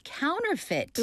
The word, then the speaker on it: counterfeit: C-O-U-N-T-E-R, counter, 그리고 그 뒤에 F-E-I-T. 발음 다시 한번 확인해 주세요. – Counterfeit. Counterfeit. 자, 그냥 fit 같지만 이게 여기서 e가 하나 숨어 들어가 있어요.